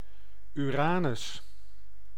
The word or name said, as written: Uranus